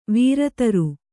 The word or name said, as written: ವರುತ್ರ